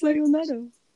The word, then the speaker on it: sayonara: No, it's, yeah, it's sayonara.